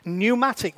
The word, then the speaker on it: pneumatic: We drop the P. The P is silent. So now it doesn't become pneumatic. It becomes pneumatic.